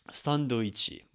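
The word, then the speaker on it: Sandoichi (サンドイッチ): Sandoichi would be an example of this. Sandoichi or sandoichi is the way this is pronounced.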